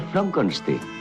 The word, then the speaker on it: Frankenstein: Se pronuncia Frankenstein.